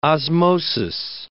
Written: osmosis

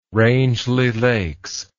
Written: rangeley lakes

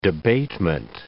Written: debatement

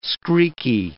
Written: screaky

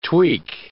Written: tweak

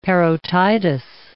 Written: parotitis

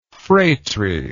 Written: fratry

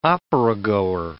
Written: operagoer

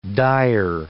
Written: dire

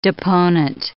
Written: deponent

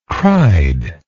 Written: cried

